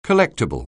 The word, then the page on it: collectable: anglais